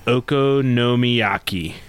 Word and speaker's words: okonomiyaki: So, smoked goose leg ham okonomiyaki.